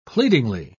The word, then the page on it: pleadingly: British English